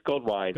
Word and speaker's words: Goldwein: Is it Goldwein or Goldwein? I want to get your name right. – It's Goldwein.